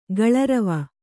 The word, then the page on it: ಕಲ್ಲುರಿವೆ: kallurive